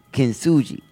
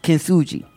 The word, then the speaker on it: Kintsugi: Kintsugi. But it's a K. Wait, you said, let me do it. You say. Kintsugi. – Uh-huh. – So K-I-N-T. – Uh-huh. – S-U-G-I.